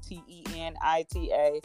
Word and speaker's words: Tenita: So if you search on Facebook or Twitter or LinkedIn, Tanita Bestseller, TENITA Bestseller, last name is Johnson.